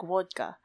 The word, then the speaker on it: vodka: I guess if I were being proper, I would pronounce it right. It's supposed to be like vodka, more of like a W sound.